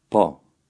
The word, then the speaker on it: po: In number 3, you'll notice that the Albanian P is also less explosive than its English counterpart. Listen and repeat. – Po.